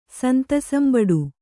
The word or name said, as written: ಸಂತೋಸಂಬಡು